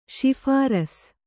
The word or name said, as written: ಶಿಫಾರ್ಸು